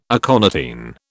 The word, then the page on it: aconitine: British English